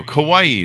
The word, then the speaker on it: Kauai: Kauai. Is that how I'm supposed to pronounce it? – Yeah.